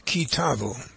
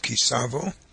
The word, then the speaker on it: Ki Tavo: The parsha that we're going to study today is Ki Tavo, sometimes pronounced Ki Savo, book of Deuteronomy.